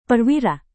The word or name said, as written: perwira